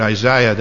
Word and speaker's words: Isaiah: By the way the British say it, Isaiah, that's closer to the way it should sound in the Hebrew.